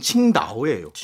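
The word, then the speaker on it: 칭다오 (青岛): 그건 중국어로 하면 칭다오예요.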